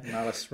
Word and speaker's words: Malus: Malus rhymes with phallus.